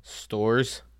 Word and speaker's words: Storrs: Stores, STORRS. How do you pronounce it? Stores.